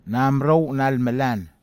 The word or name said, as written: namerou nalmelan